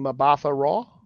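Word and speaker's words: Mbatha-Raw: It's G-U-G-U is the first name. – Yeah. – The second name is... M-B-A-T-H-A dash Raw. So I'm assuming Mabatha Raw? – Yeah, that's it.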